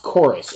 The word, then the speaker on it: Chorus: I think it's pronounced Chorus. It's just the U looks like a V.